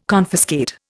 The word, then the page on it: confiscate: Indian